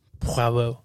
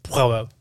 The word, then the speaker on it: Poirot: Poirot. There you go. Poirot. That's how you say it. – I'm not going to pronounce it. – You got to say it like that. Nope. You got to say it nasally.